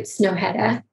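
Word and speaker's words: Snøhetta: Is it Snohetta? And I'm not even sure if I'm saying it correctly. – Yes, yes. – It's a Norwegian architect. – Correct, yes.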